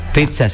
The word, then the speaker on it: pizza: Pizza, sí. Pizza. Yo también decía pizza, pero la doble Z es, se pronuncia como TZ.